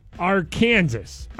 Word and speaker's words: Arkansas: The Arkansas River is pronounced Arkansas, I swear.